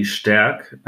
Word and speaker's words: Stark: It's actually Stark. The ST is a little bit different than Americans or any English native speakers are usually doing it.